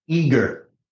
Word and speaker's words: Igor: So Igor Zagoviev has to back out... – Eager. It's pronounced eager, like I'm eager to meet you.